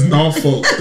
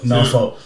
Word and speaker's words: Norfolk: It's Norfolk. That's how we say it. We country. We got country little country twang, you know. And right. It's Norfolk. So even though it looked like it's spelled, it's really spelled N A W.